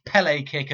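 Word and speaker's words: Pelé kick: It is pronounced Pele kick and not Pele.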